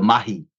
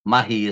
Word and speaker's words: mahi: In New Zealand, we say you got to do the mahi. Mahi is the Maori word, the indigenous people word for work. You got to do the work. – Mahi is work. Interesting. I didn't know. Spell that for me. – M-A-H-I, mahi.